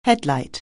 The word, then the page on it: headlight: anglais